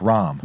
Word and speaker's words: Rahm: Ram. I want to call him Ram because Rambo. – Well, I think he calls himself Rambo. – Right, but I think it's actually pronounced Rahm.